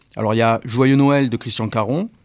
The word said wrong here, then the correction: Carion: Carion, pardon, j'ai écorché son nom,